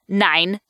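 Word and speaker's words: Nein: called Nine, spelled like, you know, the German word for no, but nine lives.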